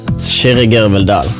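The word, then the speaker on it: Kyrre Gørvell-Dahll: My real name is pronounced Shirigirvaldal.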